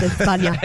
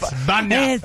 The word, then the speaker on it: España: You know, it's actually pronounced España. – It's España.